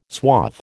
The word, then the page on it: suave: American English